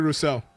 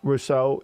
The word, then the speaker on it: Rousseau: Greg Roussard, I think his name. I always mispronounce his name. – Gregory Rousseau. – Rousseau, you know, thank you, fam.